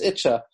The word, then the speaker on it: Yitzchak: And in Gur, in Ger, the typical way of pronouncing the name, Yitzchak is Itcha.